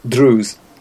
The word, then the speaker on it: Druze: And 6% is Diz. – Druze.